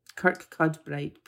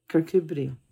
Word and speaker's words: Kirkcudbright: Kirkcudbright, pronounced Kirkcudbright.